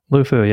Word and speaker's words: LUFU: So do you pronounce it Lufu? – Lufu, yeah.